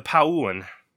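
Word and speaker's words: Pau'an: I always mispronounce this. The Pauun.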